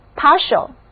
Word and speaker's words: partial: 这一字母 T 在这里读作 sh，就是一个拉长了的 S 那样的音标 sh partial。